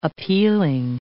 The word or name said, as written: appealing